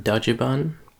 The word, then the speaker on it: Dajiban: Are you guys familiar with Dajiban? – If I say yes, can we move on? – It's literally the Japanese pronunciation of Dodge Van,